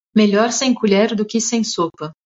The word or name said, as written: colher